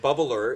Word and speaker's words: bubbler: Bubbler, like drop the R at the end too? – Yeah, of course you drop it. Bubbler is the correct pronunciation, bubbler.